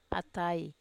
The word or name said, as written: atai